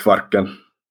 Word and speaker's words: Kvarken: Yeah, the stretch of water that connects Sweden and Finland is called Kvarken.